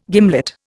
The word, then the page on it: gimlet: Indian